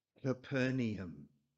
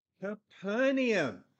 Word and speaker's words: Capernaum: Because Tafanaham, when we get it and twist it round, is pronounced Capernaum. Capernaum.